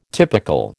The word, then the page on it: typical: American English